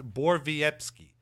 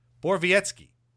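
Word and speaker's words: Borowiecki: So uh, yeah, Mark Bor it's pronounced Borvietsky. Borvietsky. Yeah, that's it. Not Bor not Borowicki, which is how it's spelled.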